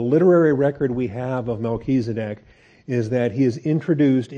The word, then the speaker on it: Melchizedek: How does the Hebrews author know that Melchizedek, if I'm pronouncing that right? – Melchizedek? – Melchizedek.